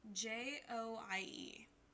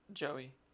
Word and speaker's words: Joie: J O I E. – Joie.